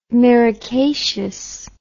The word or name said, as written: myricaceous